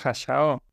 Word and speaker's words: Hayao: Yo me preguntaba por el Hayao. – Hayao. Ah, pero es Hayao.